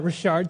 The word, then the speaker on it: Richard: So, as said, my name is Jordan Richard. Thank you for the pronunciation. It's a Cajun name, Cajun last name.